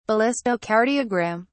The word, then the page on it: ballistocardiogram: British English